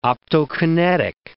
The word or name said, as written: optokinetic